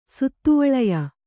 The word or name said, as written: ಸುತ್ತುವಳಯ